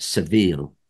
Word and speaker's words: Seville: And there's a Shakespearean joke where Shakespeare, because it's probably pronounced the same way, civil is pronounced the same way as Seville, which is a city in Spain.